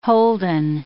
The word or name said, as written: Holden